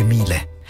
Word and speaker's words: Miele: Si scrive Miele, si legge Miele, ed è per tutto ciò che ami davvero.